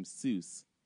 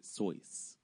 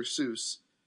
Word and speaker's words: Seuss: If you're calling him Seuss, he pronounces it Soyce.